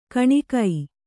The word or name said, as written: ಕುನಿಕೆ